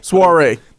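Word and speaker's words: soirée: Schwarze, Soiree. Is that Soiree? Soiree. There we go.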